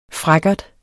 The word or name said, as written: frækkert